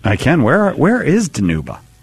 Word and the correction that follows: Dinuba: It's actually pronounced Dinuba.